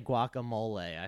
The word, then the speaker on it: guacamole: I feel like you're saying it correctly, but for like a gringo. – Yeah. – Yeah. Like, you got the mole part, and I say mole, which is wrong. – Guacamole. Oh, yeah. Mole. – Yeah, but you still say it like a gringo, so.